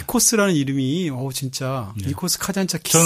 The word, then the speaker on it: 카잔차키스: 저는 니코스 카잔차스키인 줄 알았어요.